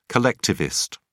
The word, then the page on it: collectivist: British English